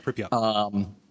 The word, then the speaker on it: Pripyat: One of the problems is the third game in the series, Call of Pripyat? I have no idea how that's pronounced. – Yeah, Pripyat.